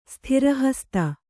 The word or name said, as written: ಸ್ಥಿರಹಸ್ತ